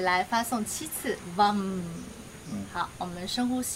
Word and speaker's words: Vam: And next we will do the Bija mantra, the seed mantra for the second chakra, which is Vam. – 接下来我们会做第二脉轮的种子音唱诵，它的发音是 Vam, V-A-M.